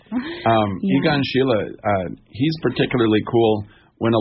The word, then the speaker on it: Egon Schiele: That was, I've been doing a lot of writing from looking at these paintings by Egon Schiele, I think is how you would pronounce it in German.